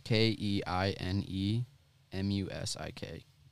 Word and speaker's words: Keinemusik: Yeah, it's it's spelled K E I N E M U S I K. Keinemusik, I'm pretty sure.